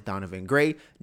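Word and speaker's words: Donivan Gray: Mine is Donovan Gray, D-O-N-I-V-A.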